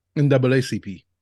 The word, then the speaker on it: NAACP: Yeah, I want to know who did the NCAAP. Am I saying that right? For some reason. – NAACP? – NAACP. – That sounds right.